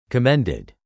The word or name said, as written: commended